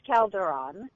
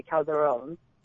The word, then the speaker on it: Calderon: It is, you did say it incorrectly. It is Calderon, or if you say it from Spain, it would be Calderon.